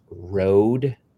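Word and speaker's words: Rhode: So the New York-based clothing, I guess it's pronounced Rode.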